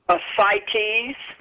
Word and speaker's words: ascites: Ascites.